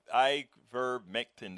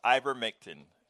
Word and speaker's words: ivermectin: It's an I word, can't pronounce it. Ivermectin. That's Gracie gave it to us. Ivermectin. Thank you, Gracie.